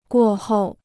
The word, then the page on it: 过后: guò hòu